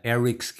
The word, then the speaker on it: Eryx: And then we are also brought to you by Eric's Gear, who's been a massive supporter of the podcast since the beginning. That's spelled E-R-Y-X Gear. And you can look him up on Instagram, Eric's Gear.